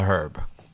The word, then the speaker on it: herb: Marijuana is not a drug; it's a herb. – It's a herb. – It has an H, so it's a herb. Is it not pronounced that way? Herb.